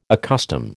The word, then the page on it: accustom: American English